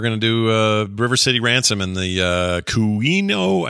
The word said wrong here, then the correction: Kunio-kun: I never say it right. Kunio Kun games. You got it. Kunio Kun.